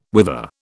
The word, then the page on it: whither: British English